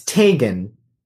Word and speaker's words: Tagen: It's Tagen. – Oh, you pronounce it with the hard G.